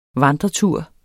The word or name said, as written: vandretur